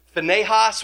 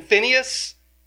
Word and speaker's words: Phinehas: Not only is it about Zimri, but it is about a very great example for us who is, you might pronounce it Phinehas. We're just saying Phinehas.